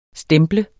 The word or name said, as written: stemple